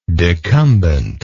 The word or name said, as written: decumbently